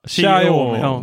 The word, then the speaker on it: Xiaomi: Xi, uh, Xiaomi.